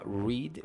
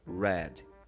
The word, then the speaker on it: read: É, tem um caso interessante aqui, porque read ele fica da mesma forma no passado, mesma forma de escrever, porém a pronúncia é diferente. Em vez de falar read, eu falo read.